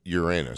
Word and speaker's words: Uranus: Every 84 years, there is a planet that's called Uranus, and it is, or as some would say, Uranus. – Oh, please. Yes, Uranus. Uranus is another pronunciation.